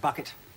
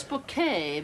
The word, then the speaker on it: Bucket: It's bouquet, Vicar. – Oh, I'm sorry. – Is that for me? – It says bucket on the envelope. – It's bouquet. B-U-C-K-E-T. Bouquet. The accent on the second syllable.